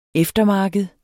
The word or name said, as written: eftermarked